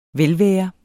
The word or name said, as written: velvære